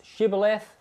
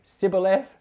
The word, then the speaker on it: Shibboleth: And then they would say, "Pronounce Shibboleth." And apparently they couldn't pronounce their SHs. So they could only say Sibboleth.